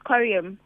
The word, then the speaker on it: Coruim: Is it Coruim? Am I pronouncing it correct? – So yes, it's Coruim.